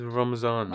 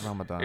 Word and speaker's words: Ramadan: It's Ramadan, innit? So you're fasting. – Ramadan, innit? It's Ramadan, bro. Fix that. – Oh, shut up, it's Ramadan.